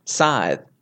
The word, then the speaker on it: Scythe: Scythe. – That's how you say it. – Is that how you say it? – You say it as if it was spelled S-I-T-H-E.